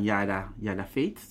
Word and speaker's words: Yada Veet: Van Yada Veet, zeg ik het goed? – Ja, dat zeg ik heel goed.